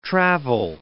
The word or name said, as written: travel